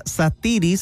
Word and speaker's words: Sathiri: Satiri se escribe S-A-T-H-I-R-I.